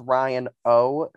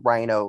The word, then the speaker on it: Ryan O: So before Lil even goes off, we get a confessional from Ryan O, who I just realized today during my rewatch that his name is pronounced Ryan O, as because his last name is starts with an O and it's not pronounced Rhino like the animal.